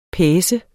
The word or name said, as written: PACENDE